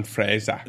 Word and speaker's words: Fraser: And her mother was actually uh, from Clan Fraser. And I, I, think I'm saying it right. I hope. Yeah, I that's that's that's right. Clan Clan Fraser.